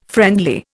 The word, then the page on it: friendly: Indian